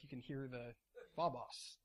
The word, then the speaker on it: phobos: It's the Greek word that's literally translated as phobos. Am I saying that right, Derek? Phobos? Phobos. Thank you.